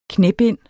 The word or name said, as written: knæbind